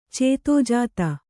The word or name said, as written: ಚಿತ್ತಜಾತ